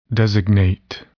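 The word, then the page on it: designate: verb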